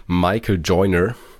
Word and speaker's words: Michael Joyner: Und zwar gibt es in den USA den Professor Dr. Michael Joyner, überragend ausgesprochen, by the way.